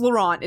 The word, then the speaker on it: Laurent: I think it's Laurent is how it's supposed to be pronounced.